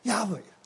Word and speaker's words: Yahweh: 耶和华原文如果音就是亚维，